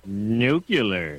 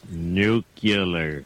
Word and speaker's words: nuclear: Nuclear. – Yes. – It's pronounced nuclear. Nuclear.